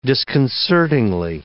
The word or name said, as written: disconcertingly